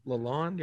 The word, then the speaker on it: Lalonde: And then Derek Lalonde, is that how you say his name? – Lalonde, yeah, Lalonde.